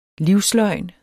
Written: livsløgn

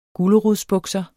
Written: gulerodsbukser

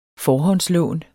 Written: forhåndslån

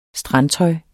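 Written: Strandtøjet

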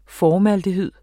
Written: formaldehyd

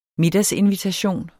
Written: middagsinvitation

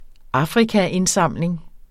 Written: Afrikaindsamling

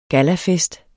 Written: gallafest

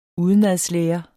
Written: udenadslæren

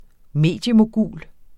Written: mediemogul

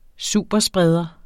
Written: superspreder

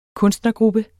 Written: kunstnergruppe

